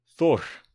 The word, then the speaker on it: Þór: Thor. I Googled how to pronounce it, and that's what it said.